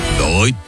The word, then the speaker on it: BRVT: Ça s'écrit BRVT, mais ça se dit Brut.